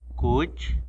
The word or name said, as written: cuch